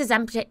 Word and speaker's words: Ozempic: There's these Ozempic, is that how you say it? – Yeah.